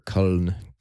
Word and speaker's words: Köln: or Köln, is kind of actually how they pronounce it.